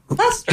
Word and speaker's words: Leicester: Leicester. – Just Chester? – Just the Earls of Leicester. A play on words. – Leicester. – Yeah, the Earls, like Scruggs, of Leicester, like flat. – Leicester.